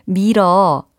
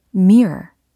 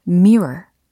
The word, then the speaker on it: mirror: 거울은 영어로 오, 미러. 어, 미러가 아니고요. 네, 잘 들어보세요. mirror 혹은 mirror 이렇게 하셔야 되겠습니다. 차이가 느껴지시나요? 첫 번째 발음했던 mirror 요거는 미국식이고 mirror 라고 하면 영국식이다 라는 차이가 되겠습니다.